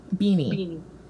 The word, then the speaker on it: Beanie: Like, I think you could do, like a Bernie Feldstein. Is that how you say her name? – Yes. – Booksmart? – Beanie.